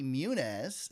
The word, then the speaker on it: Muniz: Frankie Muniz, and I pronounced his name correctly, so you don't get to laugh at me.